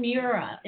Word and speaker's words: Miura: Tonight, we are talking to Susan Miura. Did I pronounce that right, Susan? – You did, yes.